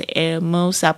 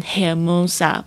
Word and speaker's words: Hermosa: 好的，第三句话我要赞美我这个好朋友，我要说的是 To Sonrisa es hermosa， To Sonrisa es hermosa Your smile is beautiful，你的笑容好美，就是 to 就是你的， Sonrisa 就是笑容是怎么样，就是 S Hermosa， Hermosa 就是 beautiful 的意思。那这边也是特别注意一下，它的这个 H 也是没有发音的，是 Hermosa，不念 Hermosa，不是这样子的。